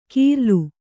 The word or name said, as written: ಕೀರ್ಲು